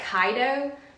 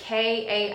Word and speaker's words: Kaiydo: It looks like Kaido. But it's Kaiydo.